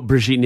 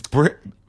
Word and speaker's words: Brigitte: Oh, Brigitte Nielsen? – It's Bridget.